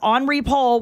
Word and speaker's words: Henri Paul: He made fun of me because Henri Paul was the driver. I didn't say Henry. I said Henri Paul because that's how you pronounce it in France.